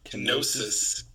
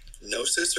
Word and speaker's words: Kenosis: Kenosis. – Gnosis or Kenosis? – Kenosis. – It's like Ken-osis.